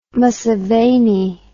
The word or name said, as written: museveni